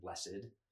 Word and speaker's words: blessed: So there's blessed and blessed. Mm-hmm. Blessed is actually a manner in which you go through life, but blessed, which legitimately they're spelled exactly the same. It's just you should have an accent marker, and one's on the first E and one's on the second E.